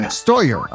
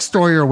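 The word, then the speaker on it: Steuer: We have Eric Stuer with us today. Is that pronounced correctly, Stuer? – It's pronounced Stoyer, actually. – Stoyer. Okay, that's an interesting name. We have Eric Stoyer with us today,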